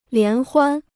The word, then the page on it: 联欢: lián huān